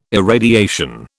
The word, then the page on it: irradiation: British English